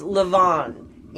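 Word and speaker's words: LaVon: It's Levon. And it's French. And the way it's spelt is L-A, capital V-O-N. It's not L-A-V-O-N-N-E like most of them.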